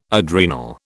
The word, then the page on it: adrenal: British English